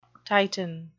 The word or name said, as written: titan